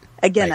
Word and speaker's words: Agena: Agena.